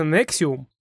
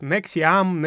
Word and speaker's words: NXIVM: Да, и это можно прочитать как Nexiam, Nexium.